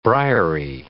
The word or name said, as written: briary